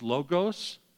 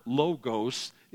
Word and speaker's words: logos: It is not logos, it's not long O's in the original language, it's short O's, it's logos.